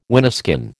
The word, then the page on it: wineskin: American English